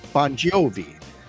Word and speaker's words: Bongiovi: John Bon Jovi, better known as John Bon Jovi, but his actual real last name is spelled B-O-N-G-I-O-V-I. Bon Jovi. But Bon Jovi kind of comes off better.